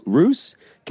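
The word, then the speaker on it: Ruess: Roos.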